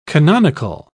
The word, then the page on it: canonical: British English